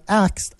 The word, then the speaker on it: ask: Pronounce the word ASK for us, would you? – Axe. – What's that? – I asked a question. – I asked a question? You're trying hard. But after years and years of saying axed, it's hard, isn't it? – It's hard. I asked. Is that better? – I asked, like ask, ask with a K on the end.